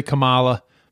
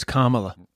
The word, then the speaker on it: Kamala: I used to say Kamala, but it's Kamala.